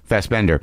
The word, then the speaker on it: Fassbender: Because, like, you know, when you talk the way you're talking about Fassbinder, does he pronounce it Fassbinder or Fassbinder? – Fassbender.